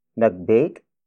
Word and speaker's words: nag-bake: Nag-bake ako ng cake para kina Tyler at Ben.